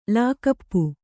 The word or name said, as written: ಲಾಕಪ್ಪು